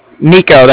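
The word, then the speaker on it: Niko: Nico, that's N-I-K-O.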